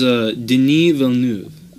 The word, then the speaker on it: Denis Villeneuve: Oh, his name is Denis Villeneuve.